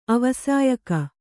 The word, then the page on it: ಅವಸಾಯಕ: avasāyaka